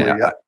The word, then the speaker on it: Cooley: William Quile or Cooley. I always get his name wrong. Yeah, I mean, Cooley, yeah. I